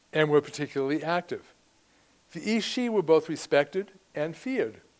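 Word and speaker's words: aos sí: This meant the spirits or fairies, or Ís-Sí in Gaelic, and that's another one that's spelled nothing like it sounds, A-O-S-S-I. Is A-O-S, Ís-Sí.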